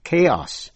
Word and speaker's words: chaos: Có khi âm CH đọc là K như trong ba chữ ta đã học trong bài trước. Đó là chaos, C-H-A-O-S, là sự hỗn loạn.